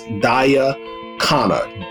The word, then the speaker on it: Khanna: I think her name is pronounced Daya Khanna. Daya Khanna, K-H-A-N-N-A.